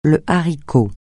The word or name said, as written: haricot